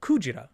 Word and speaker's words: Kujira: And the Japanese word for whale, Kujira.